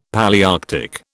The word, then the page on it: Palaearctic: British English